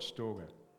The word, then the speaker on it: storge: So we'll start with storge, which you will sometimes hear pronounced storge, sometimes storge, sometimes storge. It doesn't matter. It's the same word. I'm going to call it storge.